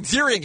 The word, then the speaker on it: Ziering: We had Iron Zierling. Am I saying that right? Sorry. – Zierling. – Come on now. – Zierling? But at some point, you should know who Ian Ziering is after having just interviewed him again.